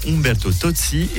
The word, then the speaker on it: Umberto Tozzi: Quant au meilleur de la musique à suivre, The Tramps, Umberto Tozzi, je vais le prononcer correctement, Umberto Tozzi et Phil Collins pour prendre davantage d'énergie positive avec Sussurio, une pépite.